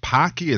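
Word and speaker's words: Paqui: I guess you pronounce it Pacui. It's P-A-Q-U-I.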